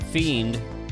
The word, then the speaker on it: F!END: All right, that one, that little lo-fi flavor was from, I think it's pronounced Fiend, but the way it's spelled is F!END, and that was their track La Fiend, or La Pursuit, and then prior to that was Wobbity with Images.